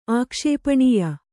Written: ಆಕ್ಷೇಪಣೀಯ

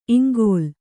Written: ಇಂಗುಲೆ

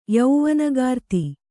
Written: ಯೌವನಗಾರ್ತಿ

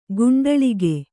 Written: ಕುಂಟಲಿಗ

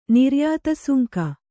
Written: ನಿರ್ಯಾತಸುಂಕ

